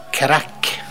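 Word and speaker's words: craic: Og da er det jo verdt å merke seg at irrene har et eget ord for det med god stemning, og det uttales crack.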